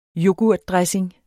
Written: yoghurtdressing

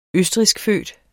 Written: østrigskfødt

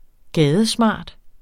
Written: gadesmart

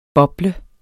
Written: bobler

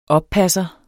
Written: OPPASSERE

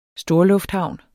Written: storlufthavn

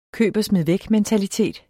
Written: mentalitet

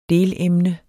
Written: delemne

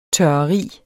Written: tørreri

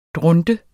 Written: dronte

